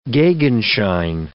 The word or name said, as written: gegenschein